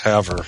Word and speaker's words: Havre: Or Havre, Montana, which is spelled like Favre, but pronounced Havre.